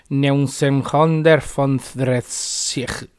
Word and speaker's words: Neunzehnhundertfünfunddreißig: Y 1935 en alemán se pronuncia Neunzehnhundertfünfunddreißig. Así más o menos, ¿no?